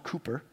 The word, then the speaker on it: Cowper: It's actually spelled Cowper, but it's pronounced Cooper.